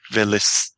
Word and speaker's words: velisav: Very beautiful is velisav. – But actually the F on the end is silent. It's silent as a final letter.